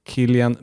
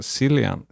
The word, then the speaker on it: Cillian: Men den viktiga är Killian Murphy, som du just lärde mig att det uttalas. Jag sa Cillian, silly me.